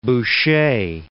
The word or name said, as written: Boucher